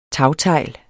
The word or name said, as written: tagtegl